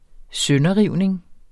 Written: sønderrivning

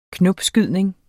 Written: knopskydning

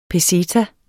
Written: peseta

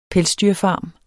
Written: pelsdyrfarm

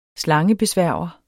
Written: slangebesværger

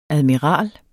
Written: admiral

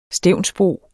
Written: stevnsbo

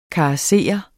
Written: KARESSERES

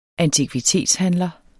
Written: antikvitetshandler

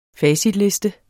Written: facitliste

